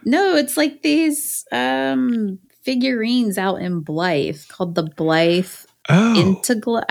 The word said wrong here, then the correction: Intaglios: I don't even know if I'm saying this right. Blythe Intaglows. And they're the Intaglios. Yeah. – Is that what it is? It probably is. See, I'm so. – It's an Italian word.